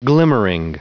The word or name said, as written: glimmering